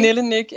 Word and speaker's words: Nille Nyc: Nille Nyc, and actually my last name, Nyc, is originally from Poland, I guess.